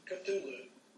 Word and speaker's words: Cthulhu: Vamos, vamos a escuchar la pronunciación correcta a Tulu. – Aquí viene un how to pronounce. Cthulhu. Cthulhu.